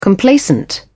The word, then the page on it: complacent: British English